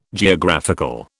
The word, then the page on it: Geographical: British English